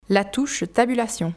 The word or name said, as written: la touche tabulation